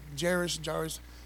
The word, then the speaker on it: Jairus: That's how I say his name. Jairus.